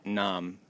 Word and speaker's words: Phnom: Pronounced Nam. – Nam. Nam Bekang, which is a temple in Siem Reap, Cambodia.